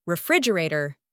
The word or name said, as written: refrigerator